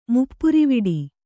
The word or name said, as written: ಮುಪ್ಪುರಿವಿಡಿ